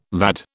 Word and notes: VAD: British English

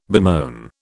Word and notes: bemoan: British English

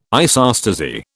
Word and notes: isostasy: British English

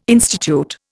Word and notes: institute: Indian

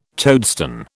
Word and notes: toadstone: British English

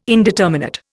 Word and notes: indeterminate: Indian

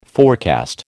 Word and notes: forecast: American English

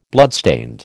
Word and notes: bloodstained: American English

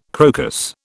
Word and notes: crocus: British English

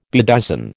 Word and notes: bedizen: American English

